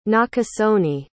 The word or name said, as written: nakasone